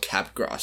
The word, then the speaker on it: Capgras: Okay, so moving into the psychology topic, it's a disorder called Capgras syndrome, which is spelled Cap and then G-R-A-S. So it's like Mardi Gras, but it's Cap Gras.